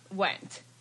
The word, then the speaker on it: Wendt: Yeah, silent D. Just Wendt. – And then, silent D? Wendt.